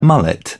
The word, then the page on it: mullet: British English